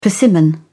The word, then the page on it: persimmon: British English